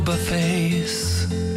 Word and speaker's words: buffet: Oh, I will grieve for no buffets. – Yeah. – Buffet, two syllables. – Yes, buffet is two syllables.